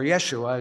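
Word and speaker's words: Yeshua (ישוע): Now, this New Testament name Jesus corresponds to the Old Testament name Joshua, or Yeshua, as they pronounce it in Hebrew.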